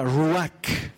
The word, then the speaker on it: ruach: Esta palabra Espíritu en hebreo se pronuncia ruach, y quiere decir viento.